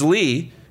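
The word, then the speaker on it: Ly: It's Lee. Alan Lee. – Okay. – So, Rod, I know it's spelled L-Y, but I found out it's Lee, and I know you'd appreciate that.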